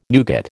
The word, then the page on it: nougat: American English